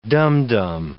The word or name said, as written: dum-dum